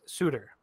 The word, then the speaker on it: Suter: They announced that they had signed, I believe it's pronounced Pius Suter, to a two-year, $1.6 million per year contract. – So not to be confused with Sutter. – Not Brandon Sutter or Daryl Sutter, but he is from Zurich, I believe, which is in Switzerland.